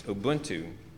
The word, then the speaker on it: Ubuntu: called Ubuntu, or Ubuntu. Sorry, I always pronounce it wrong. It's Ubuntu.